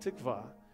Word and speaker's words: tikvah: The word hope in the Bible is the Hebrew word tikvah. I think you pronounce it tikvah.